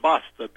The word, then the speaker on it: basta: They used the word basta, B-A-S-T-A, enough. – Okay. – It rhymes with pasta.